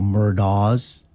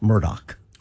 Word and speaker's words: Murdaugh: I heard people pronounce that wrong yesterday. They go Murdaugh's. It's spelled like that, but it is pronounced Murdoch.